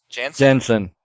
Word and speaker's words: Janssen: On the completely opposite side of that coin, we do have, and I'm going to butcher this, Famke Janssen. – Janssen.